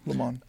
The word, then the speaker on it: Le Monde: Le Monde, is that how you say it? – Yeah, Le Monde.